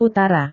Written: utara

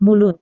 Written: mulut

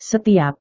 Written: setiap

